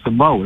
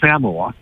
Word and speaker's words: Samoa: I've been corrected that it's not pronounced Samoa. It's pronounced Samoa.